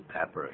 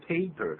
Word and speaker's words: pepper: 就是 pepper，也就是胡椒粉、可不要念成 paper， paper 是纸张、那就完全不一样了。